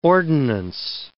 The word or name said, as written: ordinance